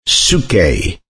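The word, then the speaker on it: psuche (ψυχή): Well, in the New Testament Greek, there is a word, psuche. It's spelled P-S-U-C-H-E.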